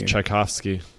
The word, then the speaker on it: Tchaikovsky: Andrew, by the way, the way you pronounce that is Tchaikovsky. – Is it kind of like the composer?